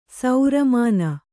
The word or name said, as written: ಸೌರಮಾನ